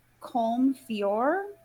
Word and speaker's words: Colm Feore: I'm going to try to say this right. Colm Feore? – Nailed it.